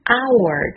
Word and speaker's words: hour: Hour 中的 h 是不发音的 silent h，所以 Hour 是一个以元音音素开始的名词，如果在前面要加上冠词，需要用到 an hour，这就对了。